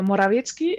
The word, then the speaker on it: Morawiecki: Por cierto, Morawiecki está pronunciado perfectamente. O sea, me gusta que lo hayas podido pronunciar bien, porque no es fácil para los españoles.